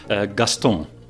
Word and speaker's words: Gaston: When you said it at first, the way that you pronounce it, you dropped the N at the end, right? It sounded almost French. – I made it nasal. Gaston. That's how the French pronounce it.